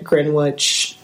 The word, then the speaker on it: Greenwich: in the private Greenwich, Connecticut neighborhood of Belhaven. – To be fair, it is spelled like Greenwich. – Yeah. It's one of those, like, old English things.